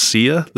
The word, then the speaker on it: Sia: The final, I don't know whether it's the final scenes are a montage to, I think it's pronounced Sia, the singer, S-I-A, the song Breathe Me.